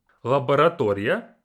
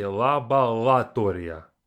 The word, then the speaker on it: Лаборатория: Лаборатория, which means laboratory. Лаборатория. Лаборатория. I always pronounce this one wrong when I say it quickly. I say лабораторія. I have to work on that one.